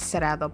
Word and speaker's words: Saredo: dramatically, so my name is saredo, but I've always gotten the saredo, mm-hmm. saredo, saredo, everything.